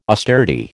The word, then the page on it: austerity: American English